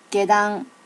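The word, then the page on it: gedan: native Japanese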